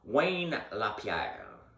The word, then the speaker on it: Wayne LaPierre: Un des vice-présidents ou le président de la NRA, il s'appelle Wayne LaPierre. Je vais le prononcer en anglais parce qu'il n'est pas québécois. Là. Wayne LaPierre.